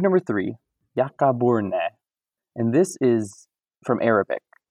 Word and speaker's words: Ya'aburnee: Ya'aburnee.